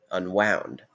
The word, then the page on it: unwound: /ʌnˈwaʊnd/